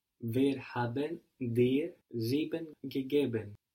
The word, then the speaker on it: sieben: Seven in German, the number seven is sieben. This is spelled S-I-E-B-E-N. Sieben.